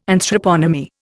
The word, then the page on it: Anthroponymy: Indian